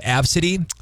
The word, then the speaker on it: ABCDE: Like ABCDE, which is actually pronounced Absidy.